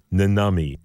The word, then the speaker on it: Nannamii: It'll be called Nanami, and that's spelled N-A-N-N-A-M-I-I. Nanami, and it'll be out on the By Norse label.